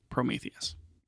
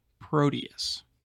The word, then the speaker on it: Proteus: It is not pronounced Prometheus. – I would have gotten that wrong. – I think maybe like Proton mixed with Gladius, which is not a word. So let's go with Proteus.